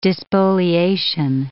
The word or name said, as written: despoliation